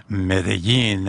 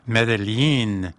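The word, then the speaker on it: Medellín: That double L is pronounced in different ways throughout Latin America. And even in Spain, they pronounce it a little different. They kind of go Elle. So it's Medellin. And in Colombia, they say Medellin. So it's a little different pronunciation.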